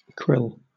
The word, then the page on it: krill: British English